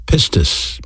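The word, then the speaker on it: pistis (πίστις): You know, faith is a noun, and in the Greek language it's pronounced pistis, P-I-S-T-I-S. It has a verb counterpart, and that's the word believe. Pisteuo, P-I-S-T-E-U-O.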